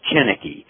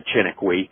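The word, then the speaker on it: Chiniquy: He says the correct pronunciation, and I'm assuming that's a French name, is Tinoco. But for many years, I said Tinoco.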